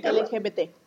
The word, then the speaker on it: LGBT: En la, en la comunidad LGTB, LGTB, eh, lo dije bien. LGBT.